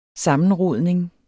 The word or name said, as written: sammenrodning